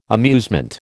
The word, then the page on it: amusement: American English